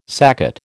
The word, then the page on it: saccate: American English